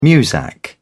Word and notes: muzak: British English

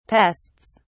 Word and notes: pests: British English

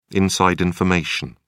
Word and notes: inside information: British English

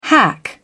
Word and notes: hack: British English

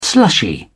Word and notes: slushy: British English

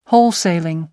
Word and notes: wholesaling: American English

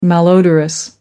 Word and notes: malodorous: American English

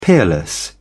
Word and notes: peerless: British English

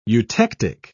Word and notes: eutectic: British English

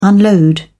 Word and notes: unload: British English

